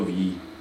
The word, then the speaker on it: Wii: Je, no jako jasné, Wii, dvě, je to jako my, že? – Jako my jenom.